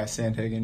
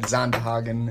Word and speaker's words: Sandhagen: Are we saying that right? – Yeah, Sandhagen, yeah. – Oh, okay. – Dutch. – Yeah, it's Dutch. Zandhagen in Dutch.